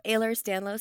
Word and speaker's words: Ehlers-Danlos: First, I just want to get the disease that you have, Ehlers-Danlos. How do you say that? – So I have hypermobile Ehlers-Danlos syndrome.